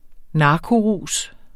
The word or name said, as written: narkorus